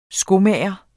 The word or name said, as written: skomager